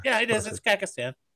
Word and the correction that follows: Kazakhstan: Send me the information. – Kazakhstan.